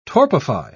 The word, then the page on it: torpify: British English